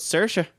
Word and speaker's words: Saoirse: Is that the girl whose name's spelled like S-A-O-R-S-E? – Yeah, it's spelled Saoirse, but she's Irish, so it's Saoirse.